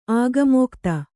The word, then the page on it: ಆಗಮೋಕ್ತಿ: kannada